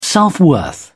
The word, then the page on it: self-worth: British English